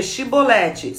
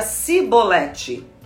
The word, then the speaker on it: Chibolete: Eles o mandavam dizer Chibolete. Se ele era de Efraim, dizia Cibolete. Pois o povo de Efraim não consegue pronunciar essa palavra corretamente.